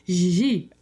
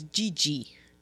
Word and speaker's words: Gigi: Gigi. – I thought it was GG. Because that's how it's spelled. – Exactly. And many people pronounce it that way. If you don't have a French flair.